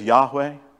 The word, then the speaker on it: Yahweh: We generally pronounce it in English as Yahweh, right?